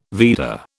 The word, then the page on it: Veda: British English